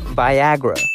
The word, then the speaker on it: Viagra: they ultimately went with the name Viagra, named for its suggestion of vitality, virility, and vigor, and all of those amazing V words. And because it rhymes with Niagara, signifying force and endurance.